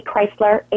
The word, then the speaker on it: Chrysler: My name, last name is spelled just like the car, and I am also all over Twitter, AB Chrysler, ABC is my Twitter handle.